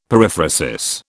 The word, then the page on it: periphrasis: British English